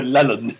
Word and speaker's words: Leland: It might be pronounced Leland. But I'm going for Leland instead. L-E-L-A-N-D.